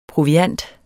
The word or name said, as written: proviant